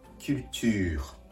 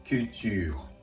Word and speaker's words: culture: フランス語で文化はキルチュール。英単語とまず比較してみましょう。英単語のカウチャー、フランス語のキューチュール。発音は違うのですが、実は綴りは全く同じなんですね。